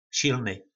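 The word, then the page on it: Silny: nářečí Po našimu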